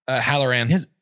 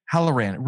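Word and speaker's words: Hallorann: I think, how was it his name pronounced? Halloran? – Halloran. – Halloran.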